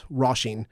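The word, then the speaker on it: Róisín: And Donegal people, we pronounce Roisin. – Roisin, yeah. – Yes. – Yeah, where, as opposed to Roisin.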